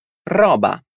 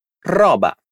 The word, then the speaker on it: roba: Dilla com'è, fai attenzione alla pronuncia della R. Roba. Roba. Perfetto.